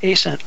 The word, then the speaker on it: Ascent: And I just said it wrong. I just caught myself. I even wrote it down. Ascent, not Ascent. Ascent Extracts.